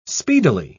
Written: speedily

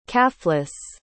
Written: caflice

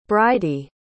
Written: bridie

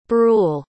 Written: brule

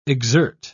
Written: exert

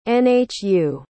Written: nhu

